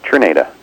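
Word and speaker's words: Czerneda: Is it Julie Zernida? – It's Cherneda, yeah.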